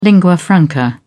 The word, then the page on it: lingua franca: British English